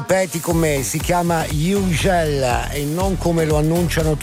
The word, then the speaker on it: Hugel: Lui è Florent Hugel, non è che un disc jockey di una radio inglese possa dire Hugel e tutti a ruota come le pecore, cioè abbiamo una dignità, facciamo questo mestiere a certi livelli, lo dovete pronunciare Hugel, con Moving to the Sun.